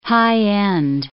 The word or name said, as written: high-end